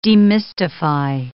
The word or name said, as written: demystify